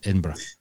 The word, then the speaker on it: Edinburgh: That's, it's, I just know how it's said properly in this context, David. Edinburgh.